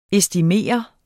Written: estimeret

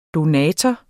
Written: donator

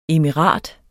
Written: emirat

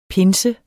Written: pinse

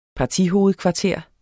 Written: partihovedkvarter

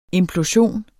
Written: implosion

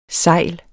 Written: segl

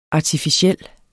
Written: ARTIFICIELLE

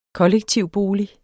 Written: kollektivboliger

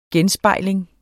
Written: genspejling

